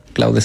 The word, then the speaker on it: Claude: Cloud se escribe Claude.